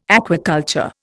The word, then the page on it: aquaculture: Indian